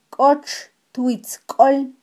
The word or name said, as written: kotxtwitzkol